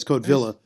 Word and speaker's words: Codevilla: Codevilla, I think, is pronounced because Villa would be Spanish. Codevilla. – Yeah, and he rejected the Spanish pronunciation. – Yeah, it's Codevilla.